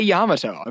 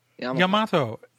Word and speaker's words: Yamato: Yamato. Not Yamato. Yamato. Not Yamato. You're putting this emphasis on the middle syllable, which is very, like, that's not how you pronounce Japanese, Nick.